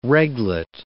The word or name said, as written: reglet